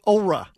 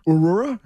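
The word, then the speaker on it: Oura: I think it's Aura only because the logo has a long a vowel sound over the O. So I'm guessing it's Aura.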